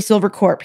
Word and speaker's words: Silvercorp: I say corp. It's spelled S-I-L-V-E-R-C-O-R-P. Which would be Silver Corp. He pronounces it Silver Corp, as in Marine Corp, but that's usually with an S.